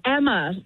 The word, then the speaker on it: Emma: Daughter, it's Emma. Yeah, pronounced like hammer, but no H.